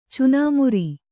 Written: ಚುನಮರಿ

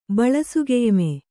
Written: ಬಳಸುಗೆಯ್ಮೆ

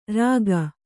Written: ರೇಗು

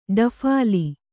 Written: ಡಫಾಲಿ

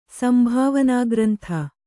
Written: ಸಂಭಾವನಾಗ್ರಂಥ

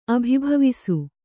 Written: ಅಭಿಭವಿಸು

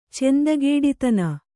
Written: ಚೆಂದಗೇಡಿತನ

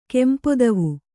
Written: ಕಂಪುದೀವು

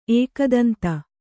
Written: ಏಕದಂತ

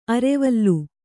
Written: ಅರೆವಲ್ಲು

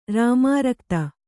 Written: ರಾಮಾರಕ್ತ

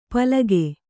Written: ಪಲಕು

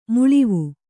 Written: ಮೇಳಾವ

